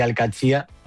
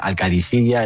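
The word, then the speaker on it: Al-Qadsiah: Bueno, se suele decir Alcaxia, pero también hay gente que dice Alcadisilla. Es que es difícil, ¿eh? Ah, no es fácil, pero Alcaxia es lo más fácil.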